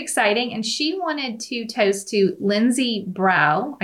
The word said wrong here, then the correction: Brou: I guess I'm saying that right. – Brou.